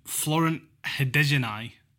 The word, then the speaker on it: Hadergjonaj: Florent Hadergjonaj.